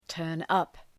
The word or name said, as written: turn up